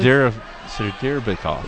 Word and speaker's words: Sadyrbekov: I probably didn't say it right, but Sadirbakov of Chartiers Valley. – Sadyr Bekov. – It's spelled S-A-D-Y-R-B-E-K-O-V. Sadyr Bekov.